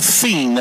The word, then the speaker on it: Thien: And if you're a Minster fan and listening, you would probably know him as Bo Thien. That is how it is pronounced over here. I think they're third or fourth cousins. But it's pronounced Tin in Fort Recovery and Thien in Minster.